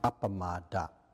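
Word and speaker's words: appamāda: In Pali, it's pronounced appamada.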